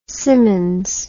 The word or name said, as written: simmons